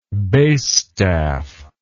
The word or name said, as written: bass staff